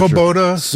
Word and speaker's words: Svoboda: Did I get that right? S-V-O-B-O-D-A party? – I'm not Svoboda. Sure. – Svoboda? – Yeah. – Yeah, there you go. – That's how you say it.